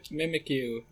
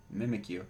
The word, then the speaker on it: Mimikyu: It mimics you. Mimikyu.